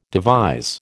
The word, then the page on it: devise: American English